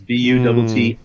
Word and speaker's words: Buttigieg: Well, in this case, the I's have it, because it's Buttigieg.